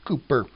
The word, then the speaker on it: Cowper: His name is spelled C-O-W-P-E-R, and some pronounce that Cowper, but the correct pronunciation is Cooper.